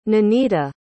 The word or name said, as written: ninita